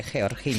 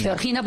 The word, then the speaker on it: Georgina: Es que lo ha pronunciado como debe, Georgina. – Georgina, porque ella es de Jaca.